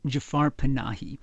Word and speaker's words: Jafar Panahi: Yes, his name is Jafar Panahi, and he is probably the person who's been the most interesting Iranian director over the last 10 or 12 years.